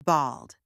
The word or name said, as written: bald